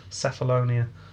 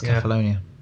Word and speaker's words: Cephalonia: Yeah, I didn't know whether it was a hard or soft C. I'm guessing soft C. Cephalonia. rather than Kefalonia. – Oh, and it's with the C. Yeah, it's Kefalonia. – Oh, is it? – Unless it's C-E-P, then it's Seth. – But it is C-E-P. – But if it's C-E-P-H, then it's Kef.